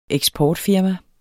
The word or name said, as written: eksportfirma